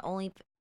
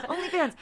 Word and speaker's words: OnlyFans: I would want her to have an OnlyFans. Did okay. – I say it right? – Yes! – Yay! Oh my God, I said it right. OnlyFans.